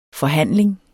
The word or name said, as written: forhandling